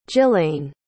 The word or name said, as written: jilayne